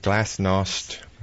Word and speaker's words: glasnost: But remember the sentence, everybody remembers that, but the sentence before that, they were talking about openness and glasnost, I believe it's pronounced.